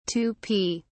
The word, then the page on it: Tupi: British English